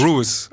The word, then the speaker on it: Ruiz: Ruiz. Also die Betonung auf dem U und dann mhm.